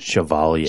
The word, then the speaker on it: Chevalier: Chevalier. Uh, she- how do you say that? – Chevalier. She- oh, geez, really? Chevalier. Okay.